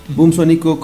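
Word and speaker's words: Bumsónico: Boomsónico, como se escucha, no como se escribe, es B-U-M, Sónico. – Todo junto. – B larga, B labial.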